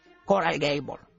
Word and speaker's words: Coral Gables: Tú sabes que cuando yo vivía en Miami, 2x3, yo vivía en Coral Gables.